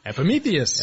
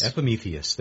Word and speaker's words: Epimetheus: He said that those two moons are Janus and Epimetheus. Did I get it right? – I was letting you pronounce it, so I didn't have to. Epimetheus. – Epimetheus. There you go.